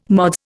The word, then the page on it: Mods: Indian